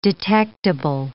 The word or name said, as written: detectable